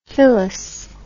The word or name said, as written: fillis